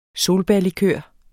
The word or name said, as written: solbærlikør